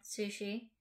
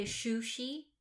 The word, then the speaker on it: sushi: So I used to say shushi, mm-hmm. – like as in like a shoe. – Mm-hmm. Who's a she? – And who's a she? Sushi.